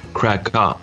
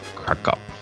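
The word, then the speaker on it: Kraków: And they pronounce it Krakow. – Krakow.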